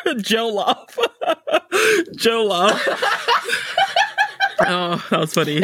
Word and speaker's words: jollof: It's just jollof rice. Jollof rice.